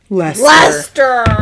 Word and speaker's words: Leicester: Lester.